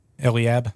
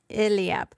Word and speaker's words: Eliab: When they came, he looked on Eliab. How do you say that? – Eliab. – Eliab.